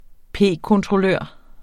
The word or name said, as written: p-kontrollør